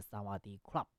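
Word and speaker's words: 萨 瓦 迪 club (สวัสดีครับ): 啊，但其实呢，这个泰语哈、啊、似乎有这个阴性阳性的这个区别，女生啊讲你好是萨瓦迪卡啊，但是男生呢啊这个发音要是萨瓦迪 club 啊。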